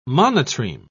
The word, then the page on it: monotreme: British English